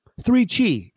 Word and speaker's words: 3Chi: 3Chi, it rhymes, is the industry leader in Delta 8 THC products.